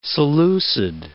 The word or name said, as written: Seleucid